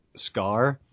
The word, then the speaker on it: Skaarj: it's this one Skaarj. It's see, uh, the J is silent,